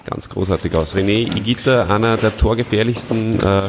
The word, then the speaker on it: Higuita: Erst Higuita, weil das H ist stumm und das G-U spricht man als G aus.